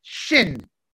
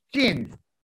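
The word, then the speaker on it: shin (שין): shin, shin, which is the word for tooth, and it's pronounced S-H, like shout, shout, shin, tooth, shenayim.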